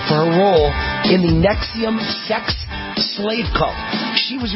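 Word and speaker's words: NXIVM: It's a sex cult, and it's pronounced Nexium. It's spelled N-X-I-V-M. N-X-I-V-M. It's pronounced Nexium.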